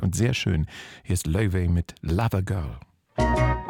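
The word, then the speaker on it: Laufey: Die nächste hier im Journal auf NDR Kultur habe ich, als sie aufkam, vor 4, 5, 6 Jahren wochenlang falsch ausgesprochen, weil ja eigentlich alles immer Englisch ausgesprochen wird. Lovey macht doch auch Sinn, aber Lovey heißt sie, kommt aus der altnordischen Mythologie.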